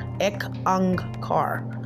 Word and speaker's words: Ek Ang Kar: It's spelled EK space ONG space car KAUR Ek Ang Kar, and it means one God, okay, one creation.